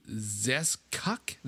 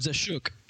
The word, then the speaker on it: Zeschuk: Zeskuk? Zeskchuk? – Anyway. – Z-E-S-C-H-U-K. So anyways, him. But Zeskuk.